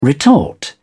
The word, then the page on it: retort: British English